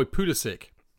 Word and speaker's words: Pulisic: And just for all of you American listeners out there who think that Jonathan and I can't say his name, your boy Pulisic.